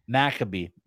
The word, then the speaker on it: Maccabi: Maccabi. There you go. – Maccabi. Just remember, it is. Like McAfee, The antivirus software, McAfee.